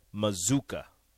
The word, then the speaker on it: Mazzucca: As a PSA, the pronunciation is Mazuka. – Okay. Mazuka. – I know things. – I'm not saying you do, but it's just, it's different. – I know. It doesn't look like it. – It doesn't look it. It shouldn't pronounce that way, but it does.